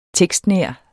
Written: tekstnær

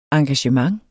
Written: engagement

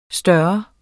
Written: stor

